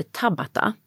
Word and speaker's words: Tabata: Och sen finns det en version av HIIT som heter Tabata. – Ja, den har jag hört talas om. – Ja, som man ska uttala på japanska.